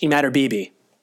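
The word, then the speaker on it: Imatorbhebhe: And I pronounced his name incorrectly. I'm gonna say it again. Josh Emadorbibi.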